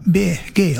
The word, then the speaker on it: Bee Gees: Og så har du at du skriver det slik som du uttaler det, så ble det Bee Gees. – Ja. – Det ikke det? Så det egentlig BG på en annen måte.